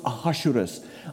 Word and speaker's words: Ahasuerus: Her husband, his name is Ahasuerus. I had to Google that, by the way, to kind of get the pronunciation right. His name was Ahasuerus.